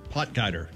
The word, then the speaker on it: Potgieter: Potgieter.